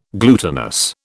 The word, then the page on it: glutinous: British English